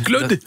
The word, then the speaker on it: Claude: Claude. – Dat spreek je op zijn Frans uit.